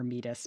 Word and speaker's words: Metis: I really do hate calling her Metis, but that is apparently the correct pronunciation.